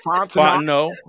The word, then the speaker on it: Fontenot: Jerry Fontenot. All right. Well, he needs to spell it with an O and don't put the T at the end. – The T Willie is silent, Rolfe.